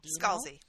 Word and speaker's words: Scalzi: Is the author's last name pronounced Scalzi or Scalzi? – Scalzi. – Scalzi, okay.